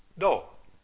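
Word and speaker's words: Do (道): La pronunciación japonesa para Dao es Do.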